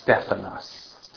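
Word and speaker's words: Stephanos (Στέφανος): Anyone know what Stephen's name is in Greek? How to say it? It's Stephanos.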